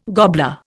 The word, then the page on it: gobbler: Indian